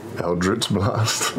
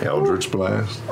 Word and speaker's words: Eldritch Blast: Eldritch Blast. – Blast, no! Well, hell. I missed the old way you said it. – Oh, nice. – Eldritch Blast.